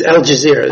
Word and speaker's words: Al Jazeera: but Al-Ghazar, I don't know if I'm saying it right. Am I saying that right? How do you say it? – It's Al Jazeera. It's the television people.